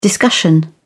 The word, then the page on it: discussion: British English